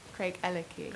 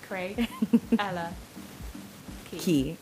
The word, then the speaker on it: Craigellachie: So it's Craigellachie. – Craigellachie. – Like Craig Ella Key.